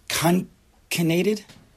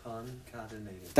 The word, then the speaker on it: concatenated: And as it, I think the word is concanated. Concanated? There's a word. Concanated. See, there's a word. You all doubted me. How do you say it? – Concatenated.